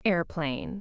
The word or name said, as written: airplane